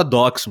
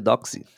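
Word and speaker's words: Docz: Eu falava Docs, mano, agora tem que falar certo, já que você falou o nome, é Doxy? Doxy? – É, o Z é meio impronunciável, né, Doxy, tipo assim.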